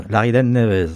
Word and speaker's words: Laridé Neves: Laridé Neves, c'est ça? – Oui, c'est ça. Très bien. – Je l'ai bien prononcé, alors.